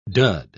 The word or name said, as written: dud